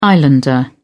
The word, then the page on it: islander: British English